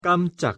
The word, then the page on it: 깜짝: Korean